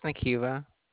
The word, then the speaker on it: Nakiva: My name is Nakiva. – How do you spell that? – Nakeiva.